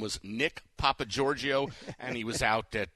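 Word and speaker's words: Nick Papageorgio: Nick Papa Giorgio, are you good with that pronunciation on the 8? – Well, first of all, it is Nick Papa Giorgio, and Nick Papa Giorgio was the fake ID name that Rusty Griswold used in the movie Vegas Vacation.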